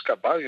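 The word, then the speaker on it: kabary: En quelques mots, de quoi s'agit-il en fait, euh, le kabari, euh, on prononce kabari, il n'y a pas d'accent avec le i pour les termes malgaches.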